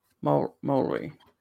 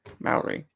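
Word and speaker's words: Māori: Pronouncing I tried this really hard this week. Maori. I think I did it. Maori. Maori. Yeah, I tried. Rolled the R.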